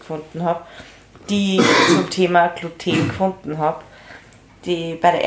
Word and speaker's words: Gluten: Das heißt nicht Glutein, du machst mich fertig, das heißt Gluten.